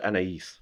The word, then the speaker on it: Anaïs: I believe it's pronounced Anaïs. – Okay. – So A-N-A-I-S. – I-S. – Ignard. I-G-N-A-R-D.